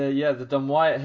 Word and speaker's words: Dumyat: I used to call it Dumyat, but it's Dumyat, is it? Is that how you pronounce it? – It's the Dumwyatt Hill race was last week.